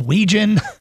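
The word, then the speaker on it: Wetjen: And Caden Wegen, if you haven't seen him, name spelled Wetgen, pronounced Wegen.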